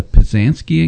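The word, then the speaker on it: pysanky: Quick aside, speaking of eggs, the North County Branch Library got a Pazansky egg. Is that how you pronounce that? – Yes.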